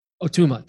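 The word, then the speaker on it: Ó Tuama: Am I pronouncing that right, Steve? Patrick, you're nodding, yes, because you, what's that? O'Tama, thank you.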